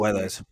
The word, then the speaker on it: Guedes: Guedes.